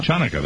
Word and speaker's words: Chanukah: Tonight is also the first night of Hanukkah, or as you Jewish people call it, Chanukah. – Chanukah, that is the correct pronunciation.